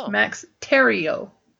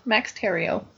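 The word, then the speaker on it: Thieriot: And I find out how to say Dylan's name. It's Max Terrio. – Oh. – So we've been saying it wrong. But anyway, now we know. Max Terrio.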